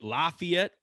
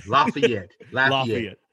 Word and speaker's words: Lafayette: I am talking with is it Lafayette or... am I saying it correctly, or am I adding too much Midwest in here? – No, you're adding too much Midwestern. Lafayette.